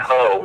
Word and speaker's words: Hoh: Yeah, and it's the river and the rainforest are called the Ho, H-O-H. But it's and you were all up in that Ho. – We were. – It's pronounced as if it was a Ho.